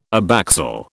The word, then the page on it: abaxial: British English